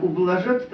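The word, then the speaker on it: ублажатъ: Вот, во-вторых, ублажат, там на конце стоит ер, твердый знак. Ублажат, да, твердый.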